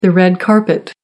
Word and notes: red carpet: American English

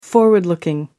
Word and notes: forward looking: American English

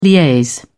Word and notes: liaise: British English

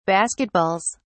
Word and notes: basketballs: British English

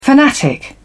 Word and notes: fanatic: British English

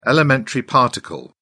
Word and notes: elementary particle: British English

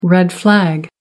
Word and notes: red flag: American English

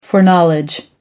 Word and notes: foreknowledge: American English

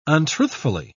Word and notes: untruthfully: British English

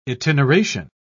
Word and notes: itineration: British English

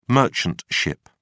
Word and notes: merchant ship: British English

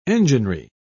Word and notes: enginery: British English

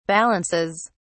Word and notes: balances: British English

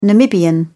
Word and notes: Namibian: British English

